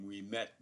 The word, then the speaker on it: Ouimet: I actually pronounced my name Ouimet.